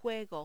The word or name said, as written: juego